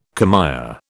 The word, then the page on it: commère: British English